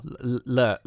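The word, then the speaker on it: Leyhr: How would you pronounce that surname? Leia, I guess, yeah. – It's L-E-Y-H-R. Leia.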